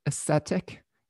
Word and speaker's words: ascetic: I have struggled with being I always pronounce this word wrong, but ascetic. I always mix that up with aesthetics, but asceticism is where you're kind of denying yourself things.